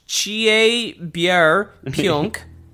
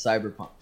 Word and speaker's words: Cyberpunk: Chierbier Punk, which I think is how they pronounce it. – Chierbier Punk. – I didn't watch the trailer, but it's Cyberpunk.